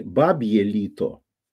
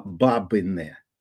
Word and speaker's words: бабине: По-украински бабье лето не бабье лито, а бабине. Бабине. Бабине лито. Вот так произносится.